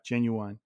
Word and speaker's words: genuine: Apparently it's genuine, not genuine. Genuine's was like a rap artist, right? But I like genuine because it just sounds like you're enunciating every syllable.